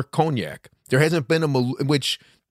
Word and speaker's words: Maluma: because otherwise otherwise Maluma, did I say it right? – Yeah, correct.